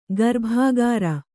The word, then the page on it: ಗರ್ಭಾಗಾರ: garbhāgāra